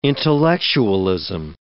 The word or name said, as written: intellectualism